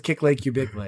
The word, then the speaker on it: Kickle Cubicle: Kickle Cubicle. – It was on the tip of my tongue. I thought we decided it was Kick Lake Cubicle. – Oh, yeah. Sorry. Yeah. – The official pronunciation.